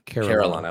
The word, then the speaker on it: Carillon: You know, outside of, I know we kind of talked about, you know, Carillon. I was mispronouncing it before.